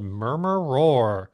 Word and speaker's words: Murmur Roar: Fight Song for the Academy is not the one we're going to play, because the one we're going to be playing is called Murmur Roar, pronounced like murmur plus roar.